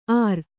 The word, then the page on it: ಆರ್: Kannada